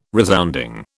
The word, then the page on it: resounding: British English